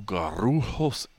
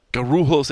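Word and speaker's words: Guarulhos: So right now I'm sitting in the Admiral's Club at the Sao Paulo Garrujos. No, you're not supposed to roll your R's in Portuguese. I apologize. Garrujos Airport, something like that. G-R-U.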